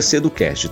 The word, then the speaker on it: Seducast: Seducast, esse T no final é mudo, então se escreve Seducast, mas a gente pronuncia Seducast, tá bom?